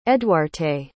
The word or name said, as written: eduarte